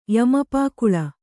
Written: ಯಮಪಾಕುಳ

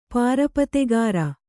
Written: ಪಾರುಪತ್ತೆಗಾರ

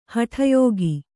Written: ಹಟಯೋಗ

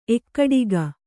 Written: ಏಕಟಾಕಿ

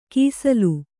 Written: ಕೈಸೋಲು

